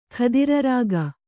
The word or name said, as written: ಖದಿರರಾಗ